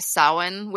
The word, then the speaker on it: Samhain: Earlier, I mentioned the holiday Samhain, which is spelled S-A-M-H-A-I-N.